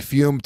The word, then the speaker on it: Fum: I'll tell you right now, I have a lot of friends who are using Fume to stop smoking. It is spelled F-U-M, but it's pronounced Fume, and it's very simple.